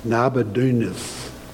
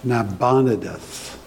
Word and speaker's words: Nabonidus: Nabonidus. No, I'm saying that wrong. Nabonidus. Nabonidus.